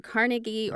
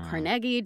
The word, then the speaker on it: Carnegie: And those people included, like, Andrew Carnegie or Carnegie, depending upon how you say it, and Henry Clay Frick.